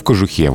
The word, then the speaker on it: кожухе: А еще в бутике ударение остается на корне и даже в, при изменении слова. В бутике вот неправильно было бы, в бутике. Но в кожухе. Вот здесь ударение переходит на окончание.